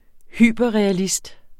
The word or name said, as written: hyperrealist